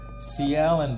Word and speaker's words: Cialyn: Anyway, T-O-U-C-H-R. You tell me what that is. C. Allen before that, that's how I pronounce it. C-I-A-L-Y-N. Track called Ouverture. Yeah, O-U-V-E-R-T-U-R-E.